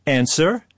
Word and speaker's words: answer: Silent P, right? Or answer. – W.